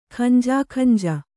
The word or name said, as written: ಖಂಜಾಖಂಜ